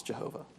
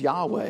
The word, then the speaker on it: Yahweh: Out pops Jehovah. Fine, I suppose. Most scholars think the best pronunciation of it is Yahweh.